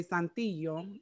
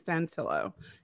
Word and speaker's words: Santillo: So, guys, like I was saying, I have Chris and Holly, even though the Latina in me wants to say Santillo, they told me their last name is Santillo.